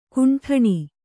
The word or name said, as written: ಖಂಡನೆ